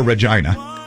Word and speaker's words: Regina: This one, this was a duet with Regina Bell. Or Regina. If you want to pronounce it that way, it's Regina Bell.